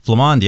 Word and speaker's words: Flamand: Did I say that right? – Flamand, yeah. – Flamand, yeah. – Okay. Little French twist there.